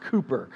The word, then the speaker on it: Cowper: It's actually, if you look it up, it's spelled Cowper, but it's pronounced Cooper.